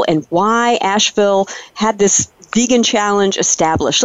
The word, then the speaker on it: Asheville: Asheville. – Asheville, got it.